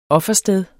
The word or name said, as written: OFFERSTEDET